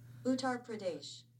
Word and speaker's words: Uttar Pradesh: Uttar Pradesh.